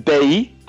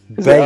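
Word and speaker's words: Bailly: You pronounce it Bay. And then I was actually on the radio this afternoon, about 6 o'clock, on Manchester XS or something like that. And they also, the presenter also said Bay. So I think it's Bay. – Bay. – I think in French, you don't pronounce the Y.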